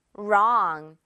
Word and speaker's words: wrong: 자, 그냥 여러분 이 W는 무시하고 wrong이라고 읽으면 되는 거예요. R부터 시작하는 거나 다름이 없죠. 발음이 똑같아요. wrong.